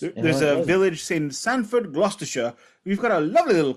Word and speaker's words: Gloucestershire: And then they rented a place called Clearwell Castle in Gloucestershire. I hope I said that correctly, all my Brits. – Gloucestershire.